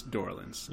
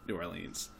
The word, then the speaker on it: New Orleans: It sounds like I'm weird because they all say New Orleans. They, like, really overpronounce it. But Norlins sounds way cooler. – I think, like, it's Norlins. – Yeah. – Not New Orleans.